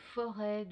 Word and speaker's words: Forez: Alors là, on a un petit peu voyagé, on est parti de Noirétable, on est descendu un petit peu vers la plaine du Forez, qu'on prononce forêt de ce côté de la nouvelle région, de la future région Auvergne-Rhône-Alpes.